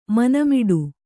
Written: ಮನೆಮುಟ್ಟು

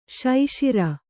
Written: ಸಸಾರ

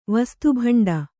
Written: ವಸ್ತುಭಂಡ

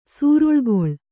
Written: ಸೂರುಳ್ಗೊಳ್

